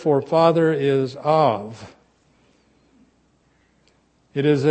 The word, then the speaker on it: Av: And it's spelled A-V. And it's pronounced Av.